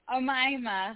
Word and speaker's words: Omayma: Omayma, hi.